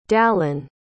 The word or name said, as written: dallen